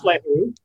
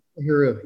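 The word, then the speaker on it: L'Heureux: How do you pronounce her last name? I think it's Leheru, yeah. – Leheru, yeah.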